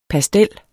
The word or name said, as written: pastel